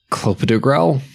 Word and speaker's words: clopidogrel: But Kate drops, their response would be morphine, IV paracetamol, which is Tylenol, and clopidogrel.